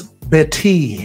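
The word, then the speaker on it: Bettye: How you even pronounce that? Is that Betty? – That's Betty.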